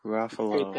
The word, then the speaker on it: Ruffalo: Mark Ruffalo. – Fake fan. – It rhymes with Buffalo.